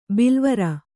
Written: ಬಿಲವರ

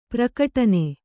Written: ಪ್ರಘಟ್ಟನ

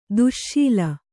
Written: ದಾಸಳ

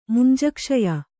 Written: ಮುಂಜಕ್ಷಯ

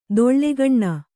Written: ತೇಲುಗಣ್ಣು

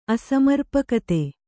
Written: ಅಸಮರ್ಪಕತೆ

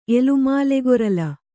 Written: ಎಲುಮಾಲೆಗೊರಲ

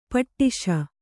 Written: ಪಟ್ಟಿಸ

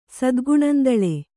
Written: ಸದ್ಗುಣಂದಳೆ

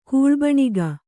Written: ಕೂಳ್ಬಣಿಗ

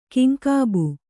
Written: ಕೆಂಗಬ್ಬು

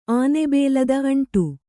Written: ಆನೆಬೇಲದ ಅಂಟು

